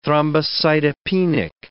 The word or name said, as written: thrombocytopenic